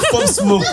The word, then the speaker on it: Pop Smoke: Pop Smoke. – C'est comme ça qu'on prononce, tu vois.